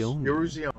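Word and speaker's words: Eruzione: Mike Iruzione. – Pretty close. Iruzione. Very good. – Iruzione.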